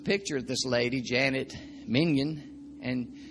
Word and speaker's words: Mentgen: So 1980, Mingen, Janet Mingen, the name is spelled M-E-N-T-G-E-N. Looks like it would be pronounced Mintgen, but I looked up the pronunciation and it says Mingen.